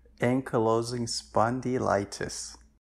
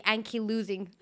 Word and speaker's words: Ankylosing spondylitis: Can you pronounce it for me? I'm scared of saying it wrong. – Yes, that's it's a tongue twister. Ankylosing spondylitis. – I was going to say Anki losing.